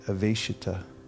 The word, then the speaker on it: Aveshita: Aveshita, that's a long A. V-E-S, like S-H, slash over it. ITA, Aveshita. Therefore,